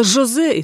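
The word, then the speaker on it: José: Только вместо «с» здесь произносили «ш», а вместо «х» — «ш». Выходило «Жозе» и только.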